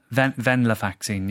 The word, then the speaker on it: Venlafaxine: A texter says, I've been on, is it Venlafaxine for a while now? – Venlafaxine, yeah. – Okay. My memory and initiative are dreadful. Any thoughts or advice? – Okay, well, venlafaxine is a medicine that we prescribe for a number of reasons, but the commonest would be low mood or anxiety.